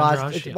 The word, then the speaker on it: Andrade: And the fight of the night was Andrade against Hill. Am I saying her name right? – Yeah, Jessica Andrade.